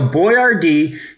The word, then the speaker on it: Boyardee: So Boyardee, his name is pronounced like you see it, B-O-Y-A-R-D-E-E. He changed it that way in marketing so people would be easier for them to read.